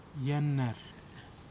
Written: yanar

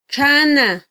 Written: txana

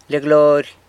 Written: Lia Glory

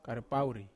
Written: karapauri

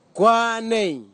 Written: kwanei

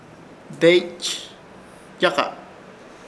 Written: Deeychyaka

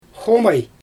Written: xomaj

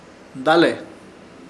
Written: Dale

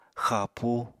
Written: xapú

